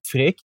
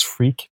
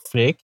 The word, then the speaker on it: Freek: Hi, I'm Freek. That's the right pronunciation. Americans can pronounce it as Freek, but it's actually Freek.